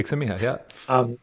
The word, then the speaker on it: Csikszentmihalyi: So I read a book a while ago called Flow by Mihaly Csikszentmihalyi. I think is how you pronounce his name. I'm sure that's right. Correct. That's what I'm talking. Csikszentmihalyi. Yeah.